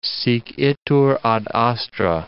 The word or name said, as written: sic itur ad astra